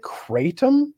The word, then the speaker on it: Kratom: And, I think Kratom, is that how it's pronounced? – Yeah, that's what they sell. That's the name of the store here. It's called CBD Kratom, and that's where I buy the Delta 8 stuff.